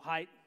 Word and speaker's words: Haidt: And I said I was going to look up how to pronounce his name, and I didn't. So Haidt is how you pronounce it.